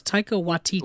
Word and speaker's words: Taika Waititi: Am I saying that right? – I think it's Taika Waititi.